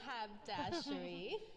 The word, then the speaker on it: haberdashery: This one is 1921, and its title is The Horner Habdashery. – The Wait. Horner Habdashery? – I said it wrong. It's called the horny haberdashery.